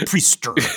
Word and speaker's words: Priestr: Like you rate the Priester priests for the Exorcist, and it's spelled P-R-I-E-S-T-R. – Right. – There's no E-R. It's just R. It's Priester.